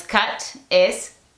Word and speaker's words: cute: La E le dice a la U que pronuncie su nombre. Ahora no es cut, es cute.